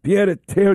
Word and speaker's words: pied-à-terre: All right, so let's get to the big story that is this pied-à-terre tax. Did I say that right, Noam? – You did.